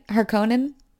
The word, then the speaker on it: Harkonnen: It's like, okay, so we get like the Harkonnen. I'm saying that right? Right? Harkonnen. – Yes.